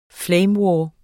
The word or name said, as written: flame